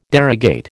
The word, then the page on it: derogate: American English